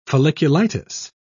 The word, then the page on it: folliculitis: British English